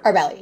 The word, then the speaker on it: Arbeli: Arbeli, yeah, you said it right.